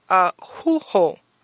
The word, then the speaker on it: akouo: So we want to come with the Greek word, and the Greek word is ahuho, ahuho, and it's spelled A-K-O-U-O.